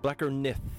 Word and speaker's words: Blakkr Nið: Blacker Nith, and the TH is spelt like an old-school Icelandic thorn.